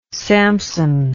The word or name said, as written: Sampson